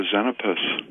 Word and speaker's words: Xenopus: Just go to Xenopus, and it's spelled X-E-N-O-P-U-S. – Mm-hmm. X-E-N-O-P-U-S.